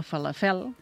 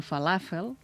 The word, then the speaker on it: falafel: Falafel, aquesta pensem que ha de ser plana, no falafel, sinó falafel. – Escriure-la, l'escrivim bé, no la pronunciem bé. – Sí, l'escrivim bé si posem un accent a l'A, eh? Falafel, clar. – Perquè és plana.